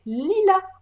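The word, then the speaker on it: Leela: Donc, Léla Turanga, c'est qui C'est quoi mais oui, qui est-ce Déjà, petite précision, moi je dis Léla parce qu'en fait, dans l'AVF, c'est comme ça que son prénom est prononcé. Mais en réalité, son prénom est prononcé Lila en anglais. Hmm, comme la fleur.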